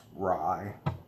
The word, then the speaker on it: Wray: W-R-A-Y is how he spells it. I think it's pronounced Rye. He wanted their heads on poles outside the White House as a message to other bureaucrats.